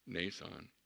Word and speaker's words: Mnason: Mnason, even though it has an M in there, I checked it real close. It's like a silent M, so it's nay with a long A, son, Mnason.